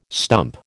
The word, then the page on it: stump: American English